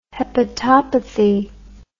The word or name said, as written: hepatopathy